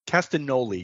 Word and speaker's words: Castagnoli: So Claudio Castagnoli, is that how you pronounce it? – Castagnoli. Yeah, I guess okay. The G is silent.